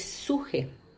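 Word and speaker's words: suje (ψυχή): Entonces, eh, en griego, por ejemplo, en el idioma original del Nuevo Testamento, la palabra para alma es suje. Eh, se escribe como P-S-U-J-E, con acento en la E, que también es la palabra raíz de psicología, donde está la psique.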